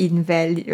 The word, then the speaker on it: invalid: The word invalid, right, is supposed to be said as invalid.